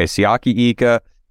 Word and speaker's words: Siaki Ika: So the Browns took defensive tackle Siaki Ika. Am I saying that right, Derek? You would know better than me. – Yeah. – I'm pretty sure that's okay. Siaki Ika.